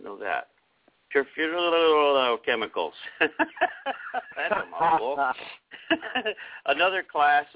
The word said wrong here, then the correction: perfluorochemicals: Perfluorochemicals, there we go, are also found in stain-resistant fabric treatments.